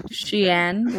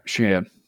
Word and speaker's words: Shyanne: It's like the clothing line. – Exactly. – Shein, like, how you spell that? – S-H-Y. A-N-N-E. Yeah, Shein.